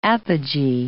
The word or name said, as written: apogee